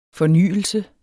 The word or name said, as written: fornyelse